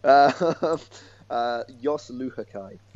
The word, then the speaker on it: Luhukay: The most important question, really, just how do we say his name? Because I've been saying Jos Luhukay. How near is that? How close is that? – It's pretty good. Jos Luhukay.